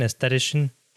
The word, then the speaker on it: aesthetician: Is that my correct pronunciation, aesthetician? Is that how you say it? – Yes, aesthetician.